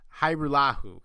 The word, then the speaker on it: Hajrullahu: Hajrullahu paused to tell a radio reporter how to pronounce his last name with a silent J, like Hajrullahu, he explained, with a nary trace of impatience at having answered this 100 times before.